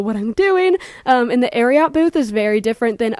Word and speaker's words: Ariat: But it is Ariat after the horse secretariat.